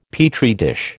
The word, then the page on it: Petri dish: American English